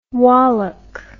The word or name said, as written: walach